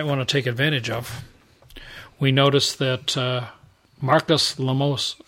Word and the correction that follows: Lemonis: how do you pronounce his name? – Lemonis is how I would say it. Looks like a Greek name.